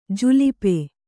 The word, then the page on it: ಜುಲ್ಪಿ: julpi